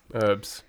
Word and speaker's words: herb: Much like herb. – Herbs. – And I am not having any of it. Niche. – No, herb is not a silent H.